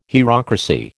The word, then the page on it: hierocracy: American English